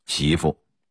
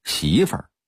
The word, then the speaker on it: 媳妇: 媳妇，普通话应该念成媳妇，但北京土话的发音是媳妇儿。